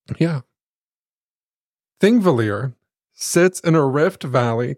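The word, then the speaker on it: Þingvellir: Thingvellir.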